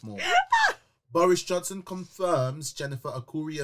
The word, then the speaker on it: Arcuri: Boris Johnson confirms Jennifer Arcuri. How do I say this name? Arcuri? – Arcuri, yeah.